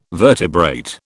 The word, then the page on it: vertebrate: British English